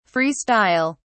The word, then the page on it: free style: British English